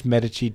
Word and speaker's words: Medici: I know it's pronounced Medici.